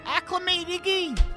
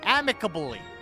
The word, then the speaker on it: amicably: And you guys will listen, and you'll understand we leave it amicably, but amicably? – You okay? – What is it? Amicably. Wait, how do you say it? How do you say the word? – Keep trying, please. – Acclimatingy. – Amicably.